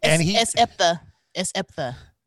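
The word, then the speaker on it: S. Epatha: But her or Jeff Goldblum or Oprah or S.E. Apathia, I always forget that actress's name. And S. Eptha. – Okay. – That's how you pronounce her name.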